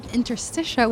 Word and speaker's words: Interstitia: Anni, you have Interstitia, which I said... – Oh, well done. You've pronounced it I've said it right, yes.